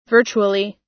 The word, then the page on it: virtually: American English